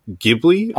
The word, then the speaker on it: Ghibli: So my first one is Studio Ghibli.